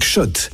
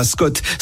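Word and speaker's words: Schott: Jouer et gagner sur RMC avec Shot, la marque iconique new-yorkaise depuis 1913. Oui, Shot et pas Scott. Ça fait des années qu'on prononce mal la marque.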